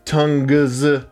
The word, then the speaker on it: tongues: T-O-N-G-U-E-S. – Yeah, it's the U after the G that throws you right. – Tongues. – Because other than that, it'd be tongs.